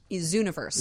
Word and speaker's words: Zooniverse: Zooniverse. So it's spelled like zoo, like zoo, and...